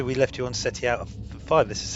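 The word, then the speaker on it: Ceti Alpha V: How do you say it properly? – This is SETI Alpha 5! – That's right. That's exactly how you say it. And no other way ever.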